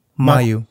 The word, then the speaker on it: mayu: And what you made was, you know, how do you say it? Maku or maku? – Mayu. – Mayu. Yeah, mayu. M-A-Y-U.